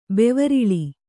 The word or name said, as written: ಬೆವರಿಳಿ